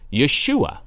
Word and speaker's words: Yeshua: Jesus is the Greek form of the Hebrew name Joshua, pronounced Yeshua.